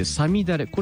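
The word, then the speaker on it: 五月雨: 昔の 5 月というのはね、なので 5 月に雨と書いてさみだれ、これのことを梅雨のことを言うんですよ。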